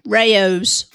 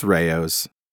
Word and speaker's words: Rao's: Rao's. – Oh, yeah. Rao's. Yes, that's right. I was called to task for not pronouncing it correctly. It's Rao's. – Not Rao's? – Not Rao's.